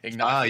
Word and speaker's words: Ignacio: How do you say his name? Ignacio. – Ignacio. Yes. Bahamondes, yes.